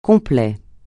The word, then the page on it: complet: francais